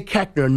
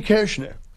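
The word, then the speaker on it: Koechner: But they say Kechner. Nowhere in the English language does the O-E make the short E sound. – Right. – But my family said Kechner. So when I got into show business, I didn't feel like... I got 36 first cousins on my dad's side. – Jesus. – And I didn't decide... I was like, I can't go, hey, by the way, you're all saying it wrong. – Right. How do they say it? – It should be Kirshner.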